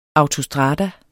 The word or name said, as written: AUTOSTRADAERNES